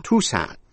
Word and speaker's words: Tucson: Và một từ ngữ nữa, Tucson, T-U-C-S-O-N, Tucson, T-U-C-S-O-N. Âm C câm, đó là một thành phố ở Arizona. Vậy tóm lại những chữ như indict, conscience, Connecticut, Tucson, trong đó âm C câm.